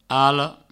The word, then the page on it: ala: alo